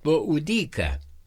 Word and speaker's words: Boadicea: Se escribe Boadicea y se pronuncia Boudica, esposa del rey de los Iseños, Prasutag.